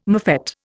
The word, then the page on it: mofette: Indian